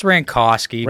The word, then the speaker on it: Rancosky: It's Rancosky. – Rancosky. – But everybody thinks it's Kowski.